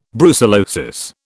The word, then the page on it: brucellosis: British English